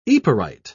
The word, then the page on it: yperite: British English